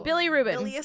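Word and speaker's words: bilirubin: Billy Rubin.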